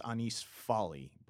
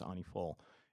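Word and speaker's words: Années folles: The French version is Annie Folle, and if you look at it spelled out, it looks like it's Anis Folly, but I believe the French pronunciation is Annie Folle.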